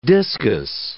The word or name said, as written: discus